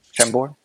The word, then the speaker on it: Chambord: Chambord? – Yeah, perfect.